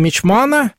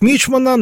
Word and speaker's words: мичман: Он в возрасте 20 лет получил чин мичмана. Сейчас принято говорить мичмана, но флот в ту пору козырял другими ударениями: мичман, рапорт, компас.